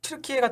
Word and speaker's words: 튀르키예: 일단 뭐 튀르카이 같은 경우는 튀르키예 같은 경우는 아 독일어로 발음했는데 튀르카이는 독일어인데 아 독일은 튀르카이라고 해요. 예, 옛날부터? – 예, 카이라고 하거든요.